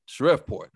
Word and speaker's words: Shreveport: Am I saying it right? – Shreveport.